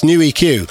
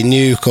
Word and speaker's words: New EQ: It's New EQ. But otherwise I would say Nuke, or I'd be going Nuek and stuff like that for ages.